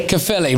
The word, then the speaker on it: Caerphilly: Is it Caerphilly? – Caerphilly, right, there you go, all right.